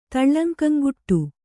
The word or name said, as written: ತಳ್ಳಂಕಂಗುಟ್ಟು